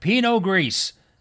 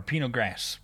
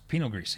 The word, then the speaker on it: Pinot Gris: And I got Pinot Gris. That's how you pronounce it. Or Pinot Gras. Pinot Gris. It's, you know, it's French. Underwood puts this in a can.